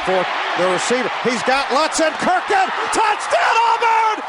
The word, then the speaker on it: Lutzenkirchen: Lutzen Kirkin. Okay, it's a mispronunciation on my part.